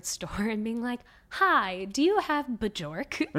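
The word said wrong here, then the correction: Björk: And I was like, I think I pronounced it right. And the guy's like, oh, you mean Bjork?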